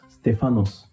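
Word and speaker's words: stephanos (στέφανος): In the Greek, it's pronounced stephanos, which means a crown.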